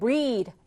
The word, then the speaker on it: breathe: 여기다 이를 붙이면 breathe, 발음이 확 바뀝니다. breath, 얘는 끝에가 th, 혀를 살짝 물어주는 th 사운드예요. 근데 breathe라고 이가 붙게 되면 이 모음과 함께 쓰여서 th가 d가 됩니다. 그래서 breathe가 되는 거예요.